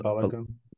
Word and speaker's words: Balogun: So how do you say his name? Florian? – Florian Balogun. – Balogun? – Fuller and Balogun.